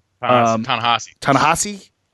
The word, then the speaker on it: Ta-Nehisi: I always say Ta-Nehisi Coates. – Yeah, Ta-Nehisi.